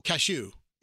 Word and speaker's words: cashew: It's spelled C-A-S-H-E-W. – Yeah. – One of my favorite of the nuts. – Right. – I pronounce it a certain way. – Yeah. I pronounce it another way. – Yes. – I say, but nobody says it the way you do. – Cashew. – It's cashew.